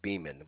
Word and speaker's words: Beeman: I believe it's the first initial M, last name Beeman, which is spelled BEEMAN, at Farmers, FARMERS, agent.